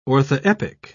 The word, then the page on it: orthoepic: British English